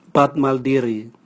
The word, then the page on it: bwatmaldiri: Raga